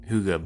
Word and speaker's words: hygge: Hygge is this Danish word. It's pronounced hygge, but it's spelled H-Y-G-G-E.